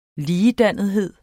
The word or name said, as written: ligedannethed